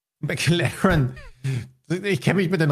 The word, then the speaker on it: McLaren: Aber das spricht man doch nicht McLaren aus. – Wie heißt das Auto? – McLaren.